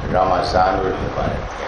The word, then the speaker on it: رمضان المبارک: اب یہاں ایک بات آپ یہ دیکھیں کہ یہ رمضان المبارک نہیں, رمضان المبارک ہے, میم کا زبر ہے. تو لفظ جو ہے اس کا جو تلفظ ہے وہ میم کے زبر کے ساتھ ہے, رمضان.